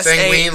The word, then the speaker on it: sanguine: Sanguine, like bloody sanguine. – Sanguine? – Yeah. – Like penguin? – Yeah, only about blood.